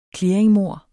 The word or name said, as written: clearingmord